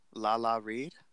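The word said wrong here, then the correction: Lala Ri: There's no D, girl. – Lala Ree. Oh my gosh.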